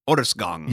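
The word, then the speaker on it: Årsgång: Well, I mean, technically, it's pronounced Year Walk. It's called Orsong. Orsgång.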